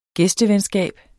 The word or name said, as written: gæstevenskab